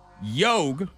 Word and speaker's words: yogh: We got win, W-Y-N-N, another W sound. Yog, Y-O-G-H. That was a yog. – A ch sound. Ash, A-S-H.